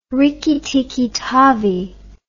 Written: Rikki Tikki Tavi

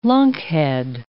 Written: lunkhead

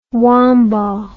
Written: Wambaugh

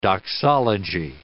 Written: doxology

